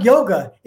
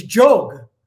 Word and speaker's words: yoga: And in Sanskrit, when the Y starts the beginning of the word, it has a J sound. – And so actually, it's not yoga, it's jog. You know, if you go to India, go to the Himalayas, it's jog, not yoga.